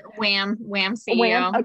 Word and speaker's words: WAHMCEO: Wham CEO, okay. Like work at home mom.